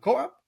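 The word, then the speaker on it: CoAP: You know, how do you pronounce co-op in English? It's this way, co-op? – Yep.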